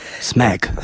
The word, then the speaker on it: Smak: Smak. – Smak. To ty tak, okej, okay, ty tak to wymawiasz.